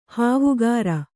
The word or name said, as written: ಹಾವುಗಾರ